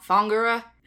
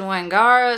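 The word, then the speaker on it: Whangara: Most damningly, the town in which Kahu lives, which should have been pronounced Fongura, because as I learned, the W gets turned into a F sound, and I said Wangara, so I was not even a little bit close.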